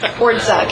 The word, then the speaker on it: Ordzak: Ordzak. – Ordzak. – It's like two syllables. – Ordzak. That's not that bad. – Think of it as no Y. Just Ordzak. Exclude the Y.